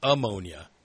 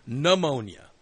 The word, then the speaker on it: pneumonia: And the amount of news people pronouncing it ammonia. She does not have ammonia. She has pneumonia.